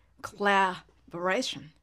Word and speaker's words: collaboration: コラボレーションはコラボレーションと発音する音なんですけれどもこのコラのラのとこですね collaboration, la というふうにですねちょっとしつこく長く口横にも縦にも広げて発音する